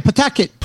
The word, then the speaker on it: Pawtucket: Ah, Aris Mercado, desde Pawtucket, Rhode Island. Am I pronouncing that right? Yes, you got it right. Pawtucket.